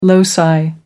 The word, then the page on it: loci: American English